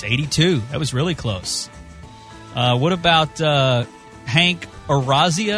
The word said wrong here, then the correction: Azaria: Am I saying that right? – Hank Azaria? – Yeah.